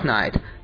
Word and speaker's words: Knight: 第二部呢是非常经典的，小布什曾经一到中国见中国的领导的时候就说，你有没有看过第二部蝙蝠侠电影，叫做 The Dark Knight，黑暗骑士 The Dark Knight，这个 Knight 就是夜晚的那个 Knight，前面加一个 K 就变成骑士的意思，发音是一样的 The Dark Knight。